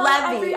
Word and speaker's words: Levy: Levy.